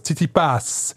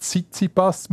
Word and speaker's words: Tsitsipas: Stefanos Tsitsipas. – Schön. Gell, nicht Tsitsipas, man tut Pass. – Tsitsipas. – Tsitsipas.